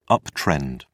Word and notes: uptrend: British English

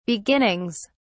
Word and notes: beginnings: British English

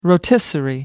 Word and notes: rotisserie: American English

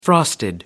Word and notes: frosted: American English

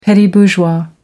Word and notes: petit-bourgeois: American English

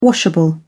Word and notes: washable: British English

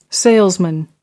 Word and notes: salesman: American English

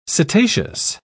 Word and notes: cetaceous: British English

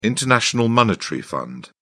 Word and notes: international monetary fund: British English

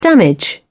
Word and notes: damage: British English

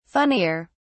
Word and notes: funnier: British English

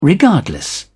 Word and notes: regardless: British English

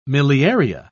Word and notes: miliaria: British English

